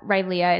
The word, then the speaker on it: Rileah: It's at Rileah. It's R-I-L-E-A-H for you that can't spell it.